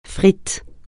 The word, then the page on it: frite: francais